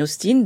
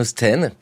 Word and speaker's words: Austen: Alors, Ariane Hudley, vous le prononcez à l'anglaise, Jane Austen, comme ten, 10 en anglais.